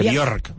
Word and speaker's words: Björk: Lo dije bien, ¿eh? Björk. – Lo dijiste estupendamente. Björk.